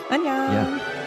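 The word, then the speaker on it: Annyeong (안녕): Annyeong.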